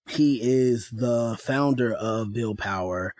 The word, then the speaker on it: BLDPWR: PWR, in reference to Build Power, that is the name of the company, how it's phonetically said, Build Power, but it's spelled BLDPWR.